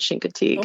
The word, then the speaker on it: Chincoteague: But as I recall, the locals pronounced it Shinkatig.